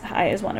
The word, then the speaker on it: IZ*ONE: And, like, I mean, all, like, a lot of the girls of Eyes One, I was corrected on how to say it. It's Eyes One, apparently.